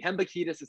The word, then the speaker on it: Hembekides: Hembekides is how it's pronounced.